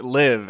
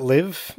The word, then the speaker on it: Live: Live. It's a live, actually. Is it live? – Yeah.